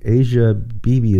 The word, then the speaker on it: Asia Bibi: And there's lots of different ways to pronounce her name. Asia Bibi is how I pronounce her name.